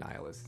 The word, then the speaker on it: nihilist: I always say Nihilist, but I think Nihilist is actually the correct way to say it, because it's nihilism.